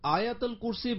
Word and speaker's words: আয়াতুল কুরসি: নামাজের পর আয়াতুল কুরসি পড়ার ফজিলত কি? – আপনি যে আয়াতুল কুরসি বললেন, আয়াতুল কুরসি বলবো না, এটা সঠিক উচ্চারণ আয়াতুল কুরসি।